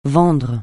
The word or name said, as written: vendre